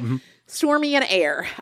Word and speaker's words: Aire: Air spelled like Air A? – A-I-R-E, and then okay. She confirmed that the pronunciation is just Air.